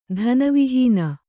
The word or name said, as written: ಧನವಿಹೀನ